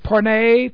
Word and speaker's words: porne: This, the Greek word is porne, P-O-R-N long E, porne, which is where, of course, we get the word pornography, the English word porno, pornography.